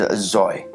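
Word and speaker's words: zoi (ζῷα): The word pronounced in the Greek manuscripts is zoi, and that means living creatures.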